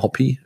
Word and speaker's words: Hoppe: Nicht Hoppe, sondern Hoppy, spricht man ihn wohl aus.